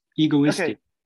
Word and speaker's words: egoistic: How do you say that one? – Egoistic.